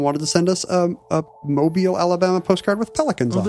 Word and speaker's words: Mobile: We also got a note from Chad in Mobile, Alabama. I said that wrong before. – Mobile.